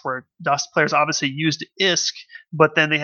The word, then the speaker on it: ISK: We were kind of, joking about it before the show, but, as you know, in EVE Online, the currency is spelled ISK, and it's pronounced ISK. In the Valkyrie, it is apparently a different currency called VISK, or V-I-S-K, which I think is actually kind of interesting.